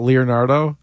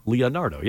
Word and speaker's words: Leonardo: How do you say it? – Replies to WEEI Afternoons in 2 hours. – Just so I can hear it? – Leonardo. – Leonardo. – Leonardo. Yeah, you got it.